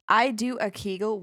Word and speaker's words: Kegel: This is about Kegel balls. I don't know if I pronounced that correctly. Or called Benoit balls. – Mm-hmm. – So them geisha balls, kind of, in Swedish. – No, we call them Kegel here, too.